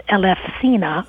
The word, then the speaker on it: Elefsina: So people would walk in procession all the way from Athens to what in modern Greek is pronounced Elefsina, and now it's an industrial city.